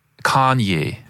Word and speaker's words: Kanye: Kanye. – Kanye, finde ich, sagst du super. – Ich glaube, die Betonung ist auf der ersten Silbe.